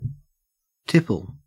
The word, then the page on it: tipple: Australian English